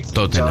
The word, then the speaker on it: Tottenham: Todos los equipos que terminan con ham, uh-huh, H-A-M, bueno, la H es muda. Ejemplo, Tottenham, Birmingham. Creo que la única excepción es West Ham, pero justamente porque se escribe separado.